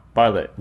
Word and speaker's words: Bartlett: Stephen Bartlett. – I think that's how you say his name. – It's Bartlett. – Yeah, it's B... Barlett. – Yeah, Barlett. – Yeah, Barlett. Yeah, perfect.